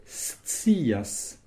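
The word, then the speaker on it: scias: Minetsias, il faut prononcer le S, puis le C qui se prononce T, donc ça fait stias.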